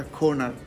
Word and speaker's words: Koner: Um, actually, my surname is the Koner not corner.